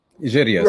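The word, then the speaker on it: Jiryis: If you speak about Zionism, you might recall that in the book, The Arabs in Israel, it was written by Sabri Giris. Is that how you pronounce his name? – Giris, right.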